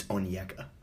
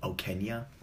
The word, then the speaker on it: Onyeka: I finally learned how to pronounce his name, by the way. It's Onyeka. I think I was pronouncing it Okenya before.